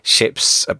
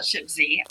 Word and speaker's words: Ships: So tell us about your ships platform. Is it ShipZ, ShipZ? How do you say it? – Well, the Americans like to call it ShipZ. I like to call it Ships.